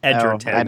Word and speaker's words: Egerton: I kept calling him Taron Egerton, and I understand now that that is totally incorrect, and it is Edgerton.